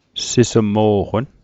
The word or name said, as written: sismo'qon